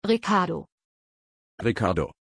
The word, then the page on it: Riccardo: German